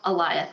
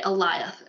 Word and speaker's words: Eliath: Al- Al- Eliath. – Okay. – I keep feeling like I'm saying it wrong. but it's like Goliath, but just with an A, Eliath.